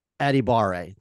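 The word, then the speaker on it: Adebawore: So if you're looking for a semi, it's Tommy Adibare. That is how you pronounce it. Is Aditamiwa Adibare. That is how you say it.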